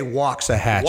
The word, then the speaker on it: Waxahachie: So is that how do you say that place? – They say Waxahachie.